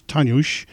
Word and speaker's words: Dagur: All this music from the release Tanjush, which means day in Icelandic, but it looks like it's spelled as D-A-G-U-R.